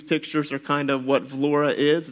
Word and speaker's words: Vlora: So we're praying about serving in a southwestern coastal city called Vlora. So you can see it down at the bottom. It looks like it's pronounced Vlor, but it's pronounced Vlora.